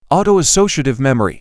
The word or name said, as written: autoassociative memory